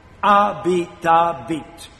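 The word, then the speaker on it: habitabit: The H is silent. Abitabit.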